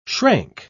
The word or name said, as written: shrank